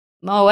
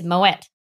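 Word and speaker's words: Moët: I mean, if it was pronounced et in French, it would have to be E-T-T-E, surely. I feel like E-T Well, no, because apparently the way that you, the legitimate way that you're meant to pronounce Moe is Moet.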